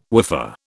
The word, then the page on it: woofer: British English